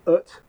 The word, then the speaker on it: Eut: So I'm going to say Ut, which is spelled EUT.